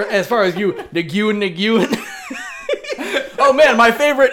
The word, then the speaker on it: Nguyen: So he was also talking about how people can name their like the first name Nguyen, which is Win, right?